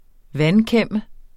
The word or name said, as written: vandkæmme